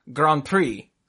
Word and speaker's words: Grands Prix: The plural of Grand Prix is Grands Prix with an S on the first one. Prix is already plural, so you have to pluralize the other bit. And it's pronounced... Grand Prix. So it's a Grand Prix. It's always a Grand Prix. It's like deer or fish.